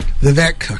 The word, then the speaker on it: Vivek: Vivek. – Okay, I didn't get it right on either cake. – One, did I? – It rhymes with cake.